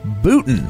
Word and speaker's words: Boutin: On the Skype-a-phone with us today is Rachel and Darryl Boutin.